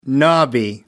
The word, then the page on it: knobby: British English